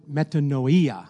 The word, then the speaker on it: metanoia: The word is meton, metanoia. I'm going to say it like a Spanish person metanoia. would say. Metanoia. Metanoia. That's how you'd say it in Spanish. it's another language.